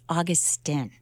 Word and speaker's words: Augustine: Brian told me that his name is actually pronounced Augustine.